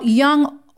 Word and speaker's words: Jung: Now, Jung also, and it's pronounced Jung,